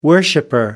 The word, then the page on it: worshiper: British English